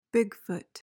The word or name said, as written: bigfoot